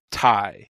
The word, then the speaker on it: Tadhg: Is that how you pronounce that? – T-A-D-H-G. Yeah, I had to Google that one. – It's Ty? – Ty, yeah. – Wow, okay. – It kind of looks like Taj.